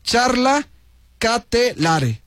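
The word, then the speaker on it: Charles De Ketelaere: Pero a bote pronto dice Charla Ketelare. Ándale, así se dice. Gracias a quien me envió este mensajito, le agradezco muchísimo. Así se pronuncia exactamente.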